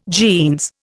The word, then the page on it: jeans: Indian